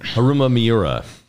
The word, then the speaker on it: Haruma Miura: Haruma Miura.